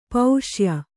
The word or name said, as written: ಪುಸ್ಯ